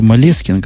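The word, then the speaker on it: Moleskine: Я не знаю, я вижу Малескин какой-то, ишь, Малескин. Я тебе расскажу. Это французское слово, ударение на последнее слово.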